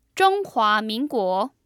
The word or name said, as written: Zhonghua Minguo